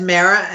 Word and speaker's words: Mara: Yeah, it's Mera, M-A-R-A.